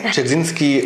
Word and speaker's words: Dzerzhinsky: Ja, Dzerzhinsky.